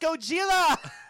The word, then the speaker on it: Gojira: Godzilla. Godzilla. – It's pronounced Gojira.